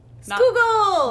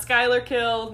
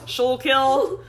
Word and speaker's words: Schuylkill: Schuylkill! – Not Skylar Kill, not however the fuck Nikita said it earlier. – Schuylkill. – Dad, next time you call me, can you tell me how you pronounce that word again? Because it was very, very funny. – Schuylkill. – I call it, it's Schuylkill, like Snoogle, the pastry from my home.